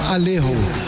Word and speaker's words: Alejo: porque em espanhol é chamado, o J é pronunciado como R, então Alejo.